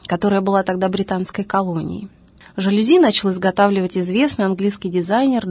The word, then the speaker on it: жалюзи: Происхождение слова французское, следовательно, произносится оно с ударением на последнем слоге – «жалюзи».